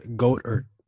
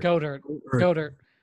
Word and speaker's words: Goedert: It's pronounced Goedert, not Goddard. – Goedert. I'll take note of that.